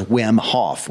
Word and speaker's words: Wim Hof: If you want to dig further is Wim Hof, which is spelled W-I-M.